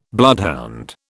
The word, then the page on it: bloodhound: British English